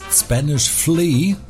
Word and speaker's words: Spanish Flea: falls ihr auch gerade denkt, ach, das ist Spanish Flea, so wird es nämlich ausgesprochen, dann seid ihr vermutlich wirklich nicht allein.